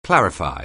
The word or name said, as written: clarify